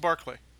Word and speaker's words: Berkeley: I think it's pronounced Berkeley. – Is it pronounced Berkeley? – I've always heard Berkeley. – That's how the Brits pronounce it.